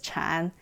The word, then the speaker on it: Chan: Following liberation from Japan in 1945, the Seong monks, Seong is Zen, as pronounced in Korean, and we pronounce it in, I'm a Chinese person, so in Mandarin we pronounce it as Chan, not the same character, which is how we recognize things coming from East Asia.